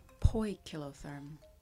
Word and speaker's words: poikilotherm: Poikilotherm.